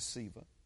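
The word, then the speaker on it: Sceva: I like to say Skiva, but it may, I think the actual pronunciation is Siva.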